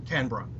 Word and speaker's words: Canberra: I presume it's pronounced Canberra? – Canberra, yes.